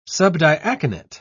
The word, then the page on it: subdiaconate: British English